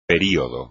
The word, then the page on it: período: espagnol